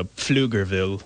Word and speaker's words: Pflugerville: It looks like a German pronunciation, and you usually go with all of the letters. So Pflugerville. – Pfluger? – Like Pferd is the German word for horse.